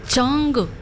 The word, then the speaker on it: Jungkook: Jungkook. – Jungkook. – Ajá. Ok, no, no pronunciamos la K, al final no se pronuncia.